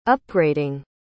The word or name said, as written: upgrading